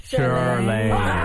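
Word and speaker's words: Chirlane: Charlaine. – Hey! Close. – Did I say it right? – Charlaine. – And I just want to tell you, I went over with him several times this morning. I was like, it's Charlaine. So make sure you say it right. – Envy, what is so hard, Envy, about Charlaine? Come on, say it with me.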